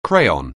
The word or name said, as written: crayon